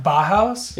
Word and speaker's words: Bauhaus: It's like the Bauhaus restaurant. – What? – Like B-A-U-H-A-U-S. – Bauhaus. – Oh, I said it. Oh, okay. Yeah. – Bauhaus? – Yeah.